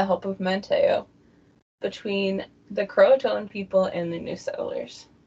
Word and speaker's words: Croatoan: Croatoan or Croatian? – Croatoan. It's spelled very similarly.